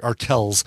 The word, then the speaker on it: Arkells: Brand new album from an artist by the name of Arkellis. Not to be confused with R. Kelly. Arkellis is spelled A-R-K-E. I'm sorry, Arkells. A-R-K-E-L-L-S.